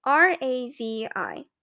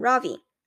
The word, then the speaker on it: Ravi: Mrs. Beam has been calling him Ravi, but when he introduced himself earlier, I'm pretty sure he said his name was Ravi, with the accent on the second symbol, syllable.